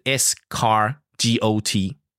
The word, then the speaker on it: escargot: 然后而且 escargot 是原发音，那也是 waiter 教我的。它的拼字是 escargot, 所以 escargot, 它是 escargot。